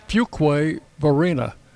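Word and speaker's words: Fuquay-Varina: It is Fuquay Varina, North Carolina.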